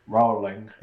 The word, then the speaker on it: Rowling: Rowling.